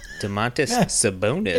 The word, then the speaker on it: Domantas Sabonis: So today we learned that the Pacers traded Domantas Sabonis. Let me say his name correctly. Domantas Sabonis.